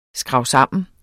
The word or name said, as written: skrabsammen